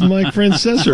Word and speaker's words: Francesa: There's no R in there. He calls himself Mike Francesa.